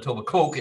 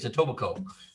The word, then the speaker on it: Etobicoke: It's amazing you say it correctly. A lot of people call it Etobicoke. It's Etobicoke.